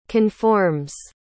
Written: conforms

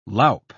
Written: loup

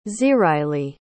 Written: ziraili

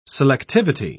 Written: selectivity

